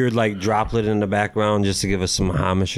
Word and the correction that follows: homage: Did I say that wrong? – I like the homage. It's homage. Amish is a religion.